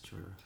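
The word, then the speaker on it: Jhoira: Yes, Joyra. The H is silent.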